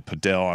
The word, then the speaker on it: padel: where you used to play the padel. I don't know if I'm saying that right. – You are, yeah.